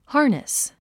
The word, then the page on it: harness: hɑ́rnəs; ハーネス